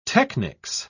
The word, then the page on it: technics: British English